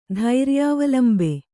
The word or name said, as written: ಧೈರ್ಯಾವಲಂಬೆ